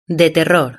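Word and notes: de terror: Spain

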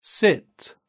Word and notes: sit: British English

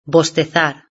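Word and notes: bostezar: Spain

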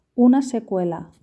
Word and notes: una secuela: Spain Spanish

